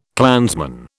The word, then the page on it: clansman: British English